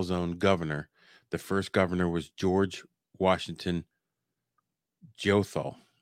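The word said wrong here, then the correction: Goethals: Geothal. Gothias. – Gothal. – Gothal. Pronunciation. I'm glad this isn't uh, barrel flicks. I'd be doing a shot right now. for saying it wrong three times.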